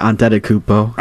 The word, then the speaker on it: Antetokounmpo: Yeah, Antetokounmpo. – Antetokounmpo was going to Miami.